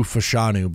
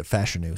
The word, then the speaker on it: Fashanu: I keep Olu, all draft process, we were saying Olu Fashanu, but he pronounces it Fashanu.